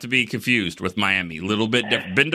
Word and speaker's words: Miami: It's not Miami. It's spelled like right. Miami, but it's Miami.